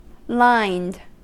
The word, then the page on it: lined: American English